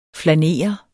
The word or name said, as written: flanere